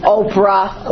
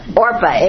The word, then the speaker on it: Orpah: There's Orpah, and I think I'm finally knowing how to pronounce her name, because this poor lady, I've given her so many different names. Oprah, Orca, it's Orpah.